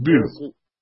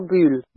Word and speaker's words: Bühl: But you don't say it correctly. It's Bühl, not Bühl.